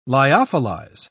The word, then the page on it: lyophilize: British English